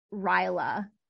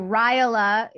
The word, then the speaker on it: Rajala: Pretty boring, but it's Jennifer and then dot Rajala. So Rajala is spelled R A J A L A.